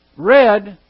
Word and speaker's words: read: Red is someone who has read something. It's reading in the past. Okay? And then you get down, for your part, read my letter. It's spelled exactly like R-E-A-D. It's R-E-A-D here.